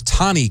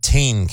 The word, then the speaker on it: Tane: I believe her name was Tani Cain, not Tane Cain, but it was phonetically looked that way. T-A-N-E, Tani Cain.